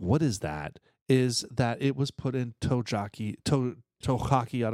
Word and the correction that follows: Tokaji: So, well, it says, ever heard of cognac or Tokaji cask finish?